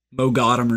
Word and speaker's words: Moghadam: I thought it was like Moghadam or something. – G is silent.